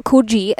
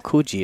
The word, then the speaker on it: Coogee: Well, okay, for our Sydney siders, or so people that have been to Sydney, that Coogee Beach, yeah? You've been there? Yeah. Well, here they have a Coogee that's not Coogee. It's spelled exactly the same, but it's Coogee.